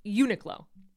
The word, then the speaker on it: Uniqlo: I've been told I pronounced that store incorrectly, and I need to correct that. Thank you to the person who told me that. It's Uniqlo.